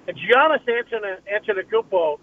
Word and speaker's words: Antetokounmpo: Well, you know, it amazes me because Giannis Antetokounmpo, thank God I said that right, he played such a great Game 6 to close out the Phoenix Suns.